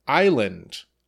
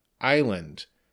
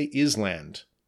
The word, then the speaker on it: island: Next, island, I-S-L-A-N-D, island. So be careful, the S is silent. We don't say island, we say island.